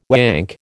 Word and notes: wank: American English

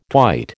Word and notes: twite: American English

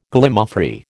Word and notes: Gallimaufry: American English

